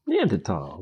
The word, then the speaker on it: Neanderthal: Neanderthal, which is the actual pronunciation of the word, which just shows how snooty she is.